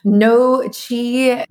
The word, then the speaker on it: Nooci: And a shout out to No Chi, which is the menstrual support product that I'm taking. It's spelled N-O-O-C-I.